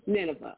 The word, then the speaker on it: Nineveh: Nineveh, okay.